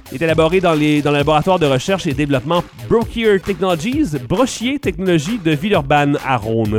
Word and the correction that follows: Brochier: Voilà, c'est Brochier. J'adore quand je prononce en anglais sans faire exprès.